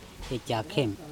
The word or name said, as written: ecakHem